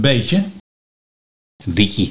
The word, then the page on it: Bietjie: Nieuw lekkerlands